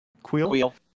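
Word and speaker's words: Kuiil: Is it Quill? – Quill.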